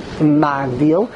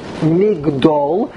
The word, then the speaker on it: Migdol: So don't make a mistake and read it Magdil, as it appears in Tehillim. Make sure you pronounce the word correctly, Migdol, as it appears in Bishmuel Beis.